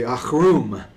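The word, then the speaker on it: achrum (עָרוּם): But that word translated cunning there, it's sometimes translated crafty in our English Bibles, and it's the word arum. In Hebrew, we would actually say achrum would be the Hebrew pronunciation, and it means cunning, crafty, shrewd.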